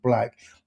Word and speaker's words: BLVCK: Also worth noting as well, earlier this year, the company signed signed an agreement to advise global lifestyle fashion brand Black. Now, Black is spelled BLVCK, so those that know it will know.